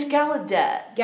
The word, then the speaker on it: Gallaudet: How do you pronounce the name? – It's Gallaudet.